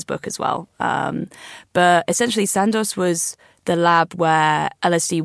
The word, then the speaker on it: Sandoz: I don't know how you say it. Sandoz? – Sandoz, I think, yeah.